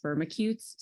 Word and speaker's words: Firmicutes: diff episode, we're going to have an I Feel Nerdy segment about the correct pronunciation of Firmicutes. Is it Firmicutes, Firmicutes, Firmicutes? But Kevin Gary says Firmicutes, so that's what I'm going to go with.